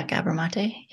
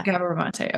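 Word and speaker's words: Gabor Maté: Yeah, Gabor Mate. Yeah. – Gabor Mate. Okay. Who is an amazing individual in his own right. So if you've ever checked out Gabor Mate's literature, his talks and stuff, I highly recommend checking him out.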